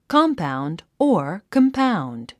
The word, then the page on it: compound: kɑ’mpaund; コンパウンド